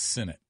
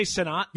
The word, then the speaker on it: Sinnott: By the way, we verified that the pronunciation is Sinnott. – Sinnott. – Yeah. Yeah. Oh, Sinnott. – Yeah. – Sinnott. – Yeah. It's okay. – Sorry. – It's new to us. – What did I say? Sanat? – Yeah. – I mean, it's spelled like Sanat, but it's pronounced Sinnott.